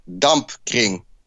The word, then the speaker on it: Dampkring: Is it the Dampkring? Dampkring? Whatever. I'll just probably cut and paste your one in. – It's the Dampkring. That's what they say in Dutch, yeah? – Okay, Dampkring.